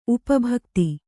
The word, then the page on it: ಉಪಭಕ್ತಿ: upa bhakti